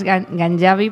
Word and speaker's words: Ganjavi: Bueno, está perfecto pronunciado, solo es Ganjavi, porque en persa y en árabe es la letra es como si fuera una Y nuestra.